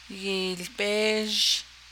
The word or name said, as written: yiilbéézh